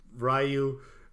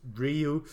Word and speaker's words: Ryu: If you put in a coin, 100 yen or a quarter, or whatever currency you're in, you play as Ryu, not Ryu. The consensus is Ryu. – That's how it's pronounced in Japan.